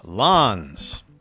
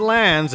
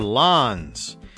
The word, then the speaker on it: Lanz: Lanz. Not Lanz, as I'd been saying before. Lanz.